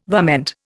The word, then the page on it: varmint: Indian